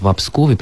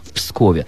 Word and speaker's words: во Пскове: Дело в том, Лора, вы абсолютно правы, что славянские языки не любят стыки согласных, и в этом случае нужно правильно говорить «во Пскове», причем я знаю, что сами псковичи очень обижаются, когда слышат «в Пскове», потому что это неверная, исторически неправильная форма.